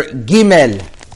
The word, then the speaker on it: Gimel: So when it has a dot, it's pretty universal that the proper pronunciation is g, gimel.